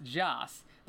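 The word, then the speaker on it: Jas (جس): The word is pronounced Jas.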